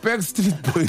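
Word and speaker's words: Backstreet: 아, 백스트리트, 아, 죄송합니다. 발음이. 아, 백을, 백을 너무 세게 했네요. 백스트리트 보이스의 노래입니다.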